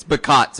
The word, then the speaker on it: Bacot: I'm pretty sure it's Bacot. It's Bacot. So I don't know why you're pronouncing it Baco. Baco. – I don't know. – Bacot.